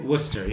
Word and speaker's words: Worcester: Worcester, pronounced the same way you in England, I guess.